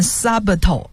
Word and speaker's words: subtle: 那么 subtle 这个词的拼法是 S-U-B-T-L-E，其中这个 B for Bob 这个是字母是不发音的，所以不念 subtle 而念 subtle。